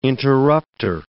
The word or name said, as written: interrupter